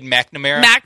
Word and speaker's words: McNamara: Cade McNow? – McNamara? – Say, he's like 50. – McNamara. – What'd you say? – Cade McNamara.